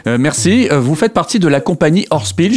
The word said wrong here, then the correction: Hörspiel: Je ne sais pas si je le dis bien d'ailleurs. Alors, moi je le dis Hörspiel. Hörspiel, ouais, voilà. – un côté un peu plus allemand, j'imagine.